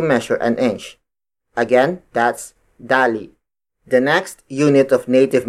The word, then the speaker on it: dali: The smallest unit of length in native Tagalog is dali. Dali. Dali. Dali is spelled as D-A-L-I.